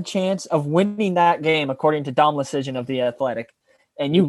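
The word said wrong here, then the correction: Dom Luszczyszyn: Loose Chishin. What? Loose Chishin. – Sorry. – Loose Chishin. Loose Chishin. It's it rhymes with loose chicken.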